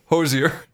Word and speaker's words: Hozier: Hozier.